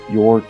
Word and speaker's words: Joerg: Joerg Rieger. Sometimes I have to sort of just spell it. First name is Joerg, and then the last name is Rieger, Rieger, and the book is Theology in the Capitalocene.